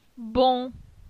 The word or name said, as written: Bon